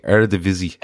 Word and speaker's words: Eredivisie: Eredivisie.